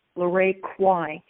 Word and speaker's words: LaRae Quy: And my name is spelled LaRae, and the last name is Quy, LaRae Quy.